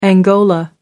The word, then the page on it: angola: American English